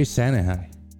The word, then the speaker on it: Sanahai: So Sherry Sanahai, right? Did I say it correctly? – Yeah. – All right. – Sherry, and then Sanahai, like Santa says hi.